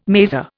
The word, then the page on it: mazer: Indian